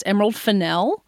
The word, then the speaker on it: Emerald Fennell: Did you know that her name is pronounced Emerald Fennell? – No, that was good to learn.